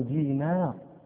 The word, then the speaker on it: دينار: النون رقيقة، النون حرف رقيق، لا نقول دينار، هذا نطق عجمي، نحن عرب نقول عبد الله بن دينار.